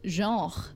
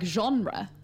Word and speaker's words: genre: In French, they have a word for it, just like a throwaway word, and it's genre. Like genre. – Oh, really? – Yeah, it's spelled genre.